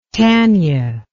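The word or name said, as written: tanyard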